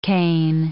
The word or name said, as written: cane